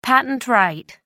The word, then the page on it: patent right: American English